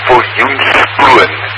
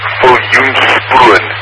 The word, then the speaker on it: Viljoenskroon: It is Viljoenskroon. Viljoenskroon.